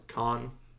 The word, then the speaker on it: Cannes: Cannes. C-A-N-N-E-S.